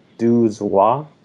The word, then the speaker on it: douze rois: No, uh, they say it's like douze rois.